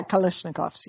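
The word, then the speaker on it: Kalashnikov: Kalashnikovs? – Yeah, Kalashnikovs.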